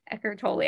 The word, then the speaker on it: Eckhart Tolle: Um, and thank you, Eckhart Tolle.